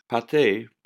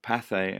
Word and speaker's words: Pathé: Pathé, or Pathé as it's pronounced by English people,